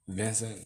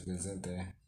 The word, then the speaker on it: Vicente: For the, for the Americans. Vincent Fernandez. – That's how you say it in English, right? – Vincent. Vincent. Oh, yeah. – But it's Vicente Fernandez.